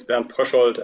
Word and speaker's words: Bernd Pröschold: And your introduction was quite fine regarding the pronunciation of my name. It's Bernd Pröschold, and the book is Reiseziel Sternenhimmel.